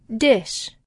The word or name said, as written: dish